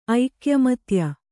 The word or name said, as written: ಐಕ್ಯಮತ್ಯ